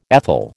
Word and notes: ethyl: American English

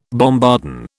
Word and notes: bombardon: British English

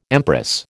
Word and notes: empress: American English

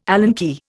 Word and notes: Allen key: Indian

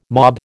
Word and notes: mob: American English

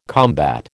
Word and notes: combat: American English

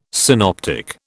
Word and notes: synoptic: British English

